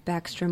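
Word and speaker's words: Backstrom: Yeah, if you just Google Jenny, J-E-N-N-I-E, mm-hmm. Baxter, we'll spell it because everyone thinks it's an X, but it's B-A-C-K-S-T-R-O-M,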